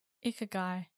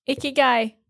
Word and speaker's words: Ikigai: Ikigai. That's my vote. That's how you say it. – Ikigai. That's how you say it. Yeah. I think you're right.